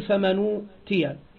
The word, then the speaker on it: فمن أوتي: حصل أن هذه الهمزة أسقطت فلا تنطق، ثم أخذت الحركة التي لها وأعطيت للإيه؟ للنون، فالنون بعدما كانت ساكن أصبحت مضمومة، وما بعدها لا يوجد همزة وإنما توجد الواو مباشرة، فتقول فمنوتيا فمنوتيا.